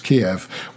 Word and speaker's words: Kyiv: Kyiv.